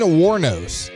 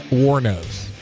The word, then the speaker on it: Wuornos: Aileen Wuornos, single cup of black coffee.